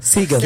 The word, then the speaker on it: Sagan: E o que a Misaira falou é sobre o astrônomo Carl Sagan, é Sagan isso. Que pronuncia? – Sagan.